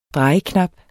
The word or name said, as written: drejeknap